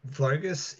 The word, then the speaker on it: Vo-Gus: Vo-Gus is the Hungarian, that's the name, Hungarian pronunciation of my last name,